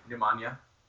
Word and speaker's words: Nemanja: But how do you pronounce his first name? – Nemanja. – It's Nemanja, huh? – Nemanja.